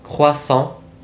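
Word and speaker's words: croissant: このクロワッサンフランス語ではクワッサンと発音し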